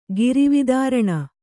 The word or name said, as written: ಗಿರಿವಿದಾರಣ